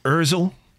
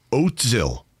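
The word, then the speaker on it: Özil: He said his name is often pronounced Ozil or Ozil, but yesterday I heard Niall Quinn call him Oatzil.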